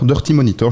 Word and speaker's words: Dirty Monitor: Et du coup, dans quel domaine on peut placer ton profil Donc tu viens du collectif Dirty Monitor, Dirty Monitor, je vais le prononcer avec un bon anglais.